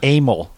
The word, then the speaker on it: amyl: It's amyl nitrate. – Anal nitrate. – Amyl. – Amyl. Oh, okay.